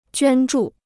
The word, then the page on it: 捐助: juān zhù